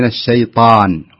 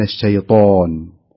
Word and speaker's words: الشيطان: الطاء الطاء الطاء دائما هذه إشكالية يقع فيها الكثير، لا يضم الشفه عند النطق بالطاء أبدا أبدا، الطاء مفتوحة، فنقول من الشيطان وليس من الشيطان، ها دائما يعني، لأن هذه يمكن إشكالية يعني يمكن الناس تعودت عليها وأصبحت عادة عند الكثير، فلا يصح أن نقول من الشيطان.